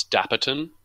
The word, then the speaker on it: Dapperton: Gus Apperton. How do you spell that? D- Gus Dapperton.